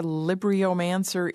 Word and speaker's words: Libriomancer: For our listeners, can you describe what a Libriomancer is? Am I pronouncing that right? – You've got it right.